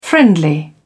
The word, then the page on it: friendly: British English